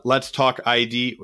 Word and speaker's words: Let's Talk ID: Let's Talk ID. Am I saying that right? What is it? – Let's talk ID. You're perfect.